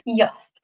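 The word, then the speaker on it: Jost: Honestly, I think I his name is Yost. Yost. Host. Uh, it's, the, it's the J. I never know how to pronounce it in like the correct European way, and I really want to try. Yost. Okay, Yost Capito. We'll go with that.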